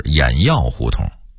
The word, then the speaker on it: 偃月胡同: 此外还有劈柴胡同的读音应该是劈柴胡同，西河沿的读音应该是西河沿，交子胡同的读音应该是教子胡同，原来的胡同名是教子胡同，偃月胡同的读音应该是偃药胡同，白纸坊的读音应该是白纸坊，西园的读音应该是西苑。